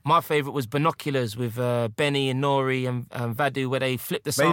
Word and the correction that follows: Vado: Was it Vado? How do you say it? – Vado. – I was Vadu.